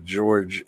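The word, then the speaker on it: Georges: Which makes my brain want to spell it George's, but I'm like, that's not. – Well, it's how it's spelled, but not how it's pronounced.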